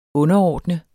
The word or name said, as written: underordnet